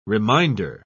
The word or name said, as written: reminder